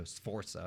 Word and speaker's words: Sforza: It's literally Forza with an S at the beginning of it, so Sforza or something.